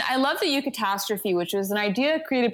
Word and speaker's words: eucatastrophe: The eucatastrophe? – Yeah.